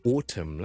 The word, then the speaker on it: autumn: 有 autumn 啦，column 啦，condemn 啦，都系 silent n sound。